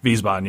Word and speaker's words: Wiesbaden: Wiesbaden, yeah, yeah.